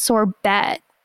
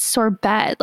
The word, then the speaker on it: sorbet: it's pronounced sorbet. And she like giggled because she thought he was joking. And he was like, no, it's sorbet, like obviously.